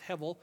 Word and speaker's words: hevel: We transliterate it in English, H-E-V or H-E-B. It's actually a soft bait, so it's usually pronounced hevel.